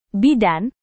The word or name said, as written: bidan